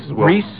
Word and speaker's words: Rice: All right, not only is Mr. Rice, or Reese, however it's pronounced, I guess it's Rice, right? – Reese. Well, that's how everybody I know pronounces it is spelled Rice.